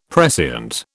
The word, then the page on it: prescient: British English